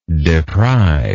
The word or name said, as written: depriver